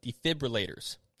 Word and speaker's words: defibrillator: Defibrillators. – Hey. – Defibrillators. Because I used to say it wrong a lot. – Defibrillator. – Right. – Defibrillator. – There's no R after the F.